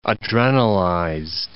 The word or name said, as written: adrenalized